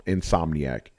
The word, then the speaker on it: Insomniac: I'm Somniac. Somniac. – Insomniac.